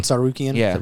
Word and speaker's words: Tsarukyan: Armand Tsarukyan.